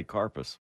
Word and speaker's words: Corpus: From around here, we say Carpus. – Carpus. – Carpus Christi? – No, you just say Carpus. I'm from down that away myself. – Oh, Carpus.